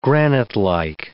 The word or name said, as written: granitelike